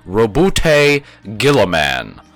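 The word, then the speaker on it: Roboute Guilliman: The correct pronunciation of his name is Robute Gilliman, or Robot Girly Man if you can't be fucked to remember that.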